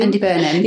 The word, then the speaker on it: Andy Burnham: And then they got Andy Bed. Don't have to say his name. – Andy Burnham.